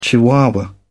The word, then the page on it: chihuahua: British English